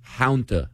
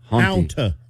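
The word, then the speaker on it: Hanta: Please pronounce it correctly. Haunta, like a haunting. – Haunta. – Haunta. – I guess just pretend there's a U in there.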